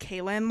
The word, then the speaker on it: Kahlyn: And then in third from Canada, we had, I believe it's pronounced Kaylin Lawson. It's Kaylin spelled K-A-H-L-Y-N, but I'm assuming that's Kaylin. She had a 49.85.